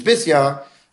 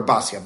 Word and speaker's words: Bisya: In the Navi, the proper pronunciation is Bisya. More popularly, we call her Basya.